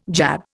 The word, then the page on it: jab: Indian